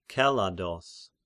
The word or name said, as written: Kelados